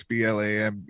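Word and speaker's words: Blame: There was uh, Blam, I believe is how it's pronounced. it's B L A M E, but it's pronounced Blam.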